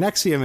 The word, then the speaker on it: NXIVM: And what we turned up was like this thing, the Knife of Aristotle, was a subset of a group called Nexium. And Nexium is spelled NXIVM.